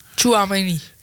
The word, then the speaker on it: Tchouaméni: Want die twee Valverde en Tchouaméni. Zeg ik dat goed? – Tchouaméni.